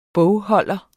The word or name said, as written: bogholder